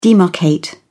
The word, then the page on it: demarcate: British English